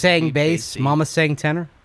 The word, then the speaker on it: Isang Bassey: He'll tell you it's Bassey, but it is Isang Bassey.